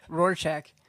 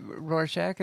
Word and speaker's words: Rorschach: Rorschach. – Ha-ha. – You should know how to pronounce that, Rorschach.